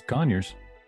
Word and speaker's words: Conyers: Did I say that right? Conyers, Georgia? – Yeah, oh, it's Conyers.